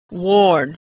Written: worn

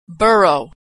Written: borough

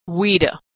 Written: we'd have

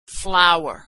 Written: flour

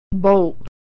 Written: bolt

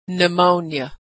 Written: pneumonia

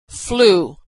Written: flue

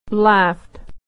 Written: laughed

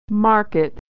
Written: market

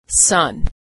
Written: sun

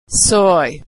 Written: soy

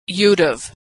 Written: you'd have

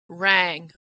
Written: rang

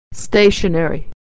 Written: stationery

